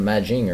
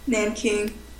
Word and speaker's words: Nanking: Like, they literally, like, they did the raping of Majing or whatever in China. – Nanking.